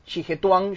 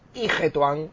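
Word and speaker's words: Yihetuan: Al Ijetuan, que era como se llamaba, que es el Xijetuan, yo hubiera pronunciado así, pero es Ijetuan, tal cual.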